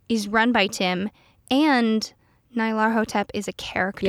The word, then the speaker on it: Nyarlathotep: So I know a lot of people say Nyarlathotep, but as I mentioned last episode, given its clearly Egyptian origins, I will pronounce it Nyarlathotep, and that's a hill I'll die on.